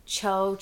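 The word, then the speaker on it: Tcho: Anyway, it is called Cho Chocolate. And it's actually spelled with a T.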